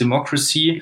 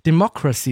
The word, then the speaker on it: Democracy: Das ist eine, eine wichtige Frage, denn äh, wenn man schon so einen komplizierten amerikanisch-englischen Titel wählt, Democracy, das heißt eigentlich Demokratie. Oh mein Gott, ich habe sogar noch, ich habe sogar noch falsch ausgesprochen. Democracy, Entschuldigung, das war natürlich mein Fehler.